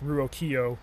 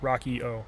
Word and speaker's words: Ruocchio: If you were to look at how it's spelled and you speak any degree of Italian, you might think that it was pronounced Ruocchio, but you know how it is in America, how we change the pronunciations of things. I don't know his family history or why it changed that, but basically think like Rocky Balboa, Rocchio.